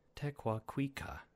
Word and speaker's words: Tekakwitha: I looked up the actual pronunciation, which is, oh gosh, Tekakwitha. But that's super difficult for me to say consistently. So, I am going to go back to the standard pronunciation.